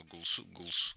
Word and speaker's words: Голсуорси: Это великое произведение Джона Голсуорси. Я даже не знаю, как его по-русски. У нас обычно Голсуорси говорят. Но там двойное ударение. Голсуорси. Вот так вот.